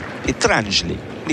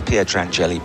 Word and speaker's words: Pietrangeli: I used to really annoy, that's a deep return from Sabianca, annoy Peter Fleming because he had this thing, it's Pietrangeli, Nicola Pietrangeli. And I used to go around saying, so yeah, tell me about this Pietrangeli bloke.